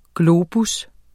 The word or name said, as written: GLOBES